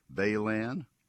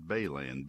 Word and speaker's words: Balan: The products you need to use include Baylan, Halts, or Dimension. That's Dimension, spelled like dimension of lumber or dimension of property. Dimension, Halts, H-A-L-T-S, and Baylan, B-A-L-A-N.